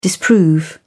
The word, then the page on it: disprove: British English